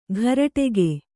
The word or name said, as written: ಘರಟೆಗೆ